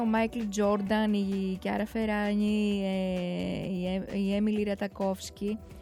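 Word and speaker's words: Ferragni: Τη λέω Φεράνι γιατί στα ιταλικά είναι το W με νι, είναι νι. Μην το κοιτάτε που λέμε Φεράνι εδώ, γιατί το Φεράνι μας φαίνεται λίγο με προφορούλα περίεργη, αλλά είναι Φεράνι.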